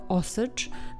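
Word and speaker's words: Osage: Jeszcze jedna uwaga, prawidłowa wymowa tego plemienia to Osycz, natomiast ja prawdopodobnie mm, tu i ówdzie będę dla wygody mówiła Osagów, plemię Osagów. Natomiast nie miejcie mi tego za złe, jest to wyłącznie dla mojej wygody.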